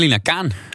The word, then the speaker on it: Lina Khan: Zeg ik het goed of zei ze Khan? – Volgens mij Lina Khan.